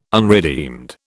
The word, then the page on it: unredeemed: British English